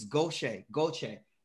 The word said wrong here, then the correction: Goethe: It's uh like G O E T H E Goethe. Göta, right? Goethe. Say the, it. The E is almost like an R.